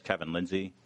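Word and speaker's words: Kevin Lindsey: My name is Kevin Lindsay. My name is spelled K-E-V-I-N. Last name is L-I-N-D-S-E-Y.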